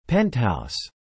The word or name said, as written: penthouse